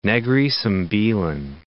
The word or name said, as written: Negeri Sembilan